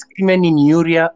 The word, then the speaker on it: crimen injuria: It's, it's you can call it it's crimen injuria or crimen, injury.